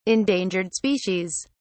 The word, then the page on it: endangered species: British English